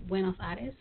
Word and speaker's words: Buenos Aires: Um, it all began with your trip to Buenos Aires. – Right. Oh, you have a great accent. you you pronounce Buenos Aires uh, very well.